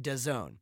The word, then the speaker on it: DAZN: It's actually written as D-A-Z-N. I think it's a kind of funky name, but it's pronounced DAZN.